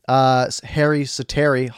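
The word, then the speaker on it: Harri Säteri: Miller feeds it over to Chiasson, who rips it past Harry Sateri, is actually how it's pronounced.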